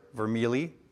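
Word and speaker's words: Vermigli: Next, Dr. Nichols introduces us to the character of Peter Martyr Vermilli. The G is silent.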